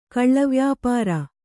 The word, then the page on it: ಕಳ್ಳವ್ಯಾಪಾರ: kaḷḷavyāpāra